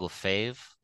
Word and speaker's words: Lefebvre: You can find me on Instagram at author Craig Lefebvre,